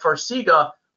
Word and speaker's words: Farxiga: Um, Will Farxiga, I'm probably pronouncing that incorrect, Farxiga, F-A-R-X-I-G-A, will that help with edema? – Yeah, so you pronounced it correctly. It's Farxiga.